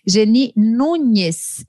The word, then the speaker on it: Geni Núñez: Geni Nunes, é assim, Geni? Estou falando certo? É isso mesmo.